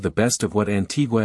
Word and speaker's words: Antigua: About Antigua and Barbuda. Antigua, pronounced Antigua, and Barbuda, Barbuda, is located in the heart of the Caribbean Sea.